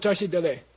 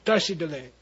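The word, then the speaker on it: tashi delek: This is the ta and tashi dale. Tashi dale. Okay? It's really tashi dale. Okay? Tashi delay. If you say tashi delay, a Tibetan will go, whoa, nice.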